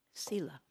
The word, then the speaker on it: sila: Sila. It's spelled S-I-L-A.